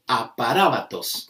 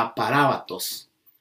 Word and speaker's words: aparábatos: Inmutable viene del griego aparábatos, con acento en la A, aparábatos, que significa intransferible, inviolable, perpetuo, inmutable, inalterable, permanente y fijo.